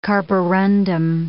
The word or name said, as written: Carborundum